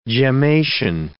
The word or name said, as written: gemmation